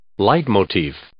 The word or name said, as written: leitmotif